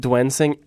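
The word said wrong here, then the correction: Duensing: every time. – Dunsing. – And one of these days I'll get it right.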